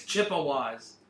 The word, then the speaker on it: Chippewas: I like the Chickawas in this one. – He keeps calling them the Chickawas. They won't say it correctly. – What is a Chickawa? – The Chippewas. – It's Chippewas.